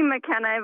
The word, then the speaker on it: McKenna: It's actually McKenna, but no worries. So I'm a 20-year-old. – McKenna. Did I say it wrong? What did I say? Mackenzie. – Mackenzie. Yes. – Like Spuds Mackenzie. Okay. You're not a bull terrier. You're McKenna. Sorry.